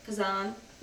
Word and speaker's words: Kazan: Kazan.